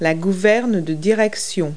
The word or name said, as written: rudder